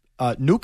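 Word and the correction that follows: Nuk: Hopkins? – Yeah, but I actually believe it's pronounced Nook.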